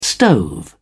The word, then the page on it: stove: British English